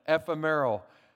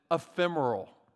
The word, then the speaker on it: ephemeral: And I pronounced it ephemeral. I kept going around just dropping this word, trying to impress people with my incredible vocabulary, calling it ephemeral. That's not how you pronounce it, okay? You actually pronounce it ephemeral.